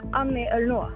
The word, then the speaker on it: Annie Ernaux: Jag ska försöka uttala det då. Annie Ernaux. Annie Ernaux, mycket riktigt.